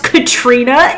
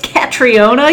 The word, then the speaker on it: Catriona: Which I thought was pronounced Katrina. It's Catriona. You pronounce the O.